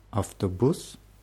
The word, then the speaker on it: автобус: Ja, вижу машину, автобус, дом и собаку. – Sehr gut. Я вижу машину, автобус, дом и собаку. Bei автобус ist die Betonung auf dem O. Автобус.